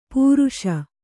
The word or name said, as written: ಪರ್ಷ